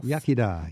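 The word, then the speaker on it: iechyd da: How do you say cheers? – In you say yachida, good health. – Yachida.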